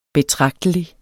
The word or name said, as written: betragtelig